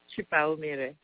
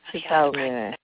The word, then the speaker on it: Chipaumire: Chipaumire. – Chipaumire. Chipaumire. – Chipaumire. It's really, it's Shona, but the best way to think about it is vowel sounds and the way you'd pronounce Spanish in a way, you know, okay.